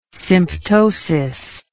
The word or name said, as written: symptosis